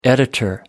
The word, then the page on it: editor: American English